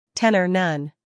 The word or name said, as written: tenor